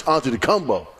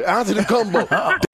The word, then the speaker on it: Antetokounmpo: The difference is that Darvin Ham is coaching LeBron James, and Doc Rivers is coaching Giannis Antetokounmpo. That's the difference. – You just butchered his name, brother. – Yeah. Whatever, Antetokounmpo. That's his name.